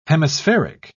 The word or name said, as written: hemispheric